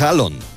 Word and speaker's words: Haaland: a decir el nombre real de Erling Haaland, que es Haaland. La doble A en los idiomas escandinavos se pronuncia como una O.